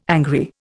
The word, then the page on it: angry: Indian